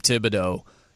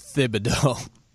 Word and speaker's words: Thibodeau: I think the NBA, poor Tom Thibodeau, no one understands the H is actually supposed to be pronounced. It's not Tom Thibodeau. Yet 99% of the time when you hear anyone say it, they say it wrong. The name is Thibodeau.